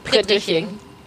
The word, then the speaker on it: Prittriching: Prittriching. – Prittriching. – Yeah, it's funny even a lot of Germans can't pronounce it. – It's a German tongue breaker.